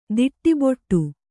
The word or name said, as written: ದಡಬಡ